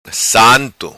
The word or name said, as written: Santo